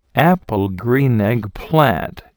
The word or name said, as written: apple green eggplant